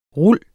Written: rul

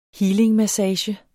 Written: healingmassage

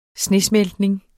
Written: snesmeltning

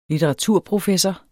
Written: litteraturprofessor

